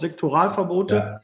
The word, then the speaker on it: Sektoralverbote: den einen oder anderen, äh, die Tendenz, äh, auch gerade in Sektionalverbote, sagt man das Sektionalverbote, äh, Sektor, Sektoralverbote.